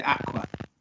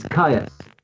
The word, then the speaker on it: Kyuss: Is it Aqua? – No. It's Caius. – I taught a child called Caius. How do I spell it? – K-Y-U-S-S.